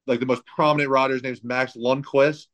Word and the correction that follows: Lindquist: Did I say that right? – Lindquist, Max Lindquist, Wesley Wolf's planning to do it, Michael Allred maybe.